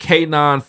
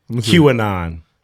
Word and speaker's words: QAnon: K-9 fanatic. – QAnon.